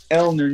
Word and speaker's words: ulnar: Ulnar nerve is how it's pronounced. Ulnar.